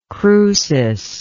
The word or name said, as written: crucis